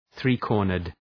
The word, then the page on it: three-cornered: ‘ɵri:,kɔ:rnərd